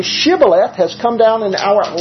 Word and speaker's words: Shibboleth: And so they asked them, say the word Shibboleth. Ephraimites couldn't pronounce the H in Shibboleth. So they said, Shibboleth, and they were killed then.